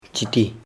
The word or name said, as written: chiti